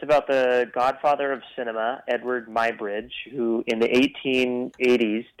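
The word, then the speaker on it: Eadweard: He is the founder of Motion 58 and director of the Vith film Eadward. I hope I am pronouncing that right. Hi, Kyle. – Hey, how's it going? Yeah, you said it right, Eadward.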